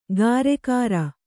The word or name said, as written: ಗಾರೆಕಾರ